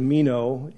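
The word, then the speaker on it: meno (μένω): The Greek word is meno, M-E-N-O.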